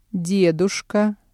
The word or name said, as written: дедушка